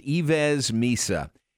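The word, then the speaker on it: Yves Missi: It's pronounced Ives Misa.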